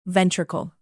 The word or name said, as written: ventricle